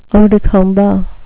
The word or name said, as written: hors de combat